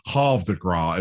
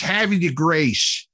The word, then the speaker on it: Havre de Grace: But since it's in Maryland, not too far from Baltimore, it's Havre de Grace. That's kind of how it's pronounced, you know.